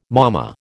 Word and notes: momma: American English

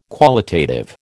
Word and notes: qualitative: American English

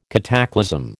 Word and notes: cataclasm: American English

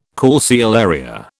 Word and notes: calceolaria: British English

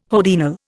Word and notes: hodiernal: Indian

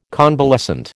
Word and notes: convalescent: American English

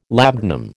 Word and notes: labdanum: American English

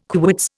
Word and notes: quits: Indian

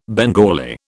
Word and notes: Bengali: British English

